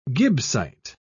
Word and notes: gibbsite: British English

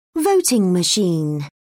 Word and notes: voting machine: British English

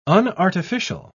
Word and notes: unartificial: British English

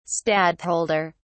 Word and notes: stadtholder: British English

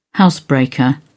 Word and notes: housebreaker: British English

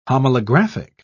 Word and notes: homolographic: British English

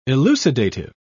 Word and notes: elucidative: British English

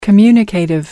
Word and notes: communicative: American English

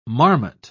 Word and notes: marmot: British English

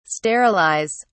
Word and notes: sterilise: British English

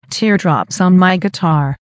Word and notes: teardrops on my guitar: American English

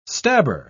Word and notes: stabber: British English

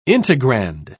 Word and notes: integrand: British English

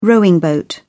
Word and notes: rowing boat: British English

